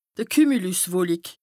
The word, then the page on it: d' Kümülüswolik: Bas-Rhin; Strasbourg